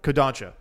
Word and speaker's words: Kodansha: Well, I mean, I say that like Kodansha. That's what it is. I was trying to remember how to pronounce the word correctly.